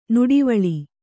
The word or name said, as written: ನಡುವಳಿ